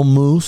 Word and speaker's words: mousse: I don't know, caramel mousse, swirl mousse. Have you had M-O-U-S-S-E? It's pronounced the same, spelt differently, okay?